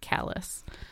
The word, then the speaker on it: Calais: So it's spelt the same, but of course in Maine, there is actually some French there, but they pronounce it Calais.